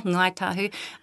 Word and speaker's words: Ngāi Tahu: Ngai, well, that you've got that NG sound at the beginning, yes. Ngai tahu. The kai tahu dialect is an interesting one because the kai tahu dialect replaces NG with a K.